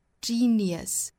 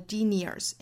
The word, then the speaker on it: genius: 我们看这个词不管英音还是美音啊，后面这个音节一定不要读成卷舌的这个的音。应该是 genius, schwa sound. 那我们不要读成了 genius, 应该是 genius.